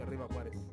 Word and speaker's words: Arriba Juárez: Ajúa, Riva Juárez, ah no, ese no es. Así no se dice Arriba Juárez.